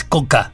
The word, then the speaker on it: Skokka: Y se escribe S-K-O-K-K-A, ¿no? Es Coca.